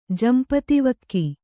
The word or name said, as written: ಜಂಪತಿವಕ್ಕಿ